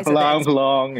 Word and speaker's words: Poulenc: Okay, and so of course the focus for this concert is Johann Sebastian Bach and Francis Poulenc. Am I saying that correctly, or is it I tried listening online, and there were various different pronunciations. – Yeah, Poulenc. Yeah.